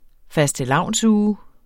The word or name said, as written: fastelavnsuge